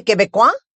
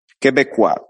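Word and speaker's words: Québécois: ¿Cómo se dice quebecois? – Quebecois, voilà. – Quebecois, muy bien.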